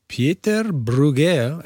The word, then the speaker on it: Pieter Bruegel: And I'm not sure if it was intended as an Easter egg or not, but The Magpie and the Gallows by Pieter Bruegel, am I saying that right? – Yeah.